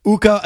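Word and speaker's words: Ukko: Uka, again, rhymes with... – Puka. – Oh, yeah. – Tuka.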